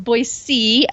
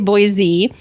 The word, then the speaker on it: Boise: I know, I know, and I'm calling you out. It's Boise. Uh, everybody's going to know you're not from here if you say Boise.